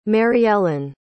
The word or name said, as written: maryellen